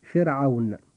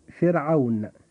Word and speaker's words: فرعون: أما كلمة فرعون فحرف الراء جاء ساكنا وقبله جاء حرف مكسور وهو الفاء، ولم يأتي بعده حرف استعلاء، فيجب أن نرقق الراء عند نطقنا لكلمة فرعون فنقول فرعون.